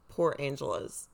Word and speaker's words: Port Angeles: Just a half hour away from her apartment in Port Angeles.